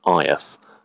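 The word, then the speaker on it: Áed: So I think one of the episodes which could have been very challenging because there was almost no information was a chap called Aeth, which is spelled A-E-D.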